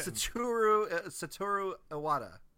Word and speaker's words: Satoru Iwata: Satoru Iwata.